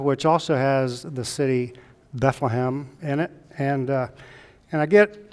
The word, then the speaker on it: Bethlehem: Most people would say Bethlehem, which is probably the right and correct pronunciation, but the Hebrews, the Jews, would drop the H on the first syllable. It would be Bethlehem, no H there.